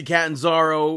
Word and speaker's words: Catanzaro: Casey Catanzaro, which, great job at pronouncing her name.